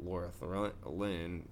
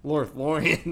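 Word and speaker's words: Lothlórien: Lorthlin. How do you say it? Lorthlin? – Lorthlorien? – Lorthlorien.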